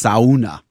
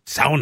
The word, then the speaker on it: sauna: Um, as predicted, somebody has corrected my pronunciation. It's sauna. Sauna. Not sauna. We, Jenny and I were laughing about that. And then sure enough, thank you, listener. I know I don't say it like the Finns.